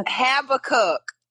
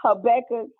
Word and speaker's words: Habakkuk: It's Habakkuk. I promise. – Okay, listen. It's Habakkuk. The one with the H.